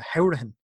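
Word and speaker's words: Hourihane: I actually realize I've already pronounced his name wrong. He likes to be pronounced uh, Howrahan, not Hurahan.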